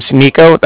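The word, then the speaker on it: Niko: So, my name's Nico. That's N-I-K-O.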